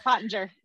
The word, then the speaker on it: Pottenger: Amy Pottenger. You did. You totally said it right. Pottenger.